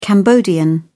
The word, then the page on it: cambodian: British English